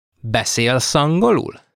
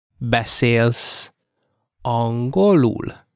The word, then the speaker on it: beszélsz angolul: In that case, you will say in Hungarian, beszélsz angolul. While the difference seems small, it is very significant. Let's say it slowly now. Beszélsz angolul.